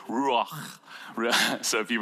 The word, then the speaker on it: Ruach: It's called Ruach, and the proper pronunciation has a very hard H or CH sound at the end.